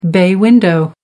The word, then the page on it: bay window: American English